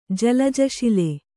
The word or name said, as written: ಜಲಜಶಿಲೆ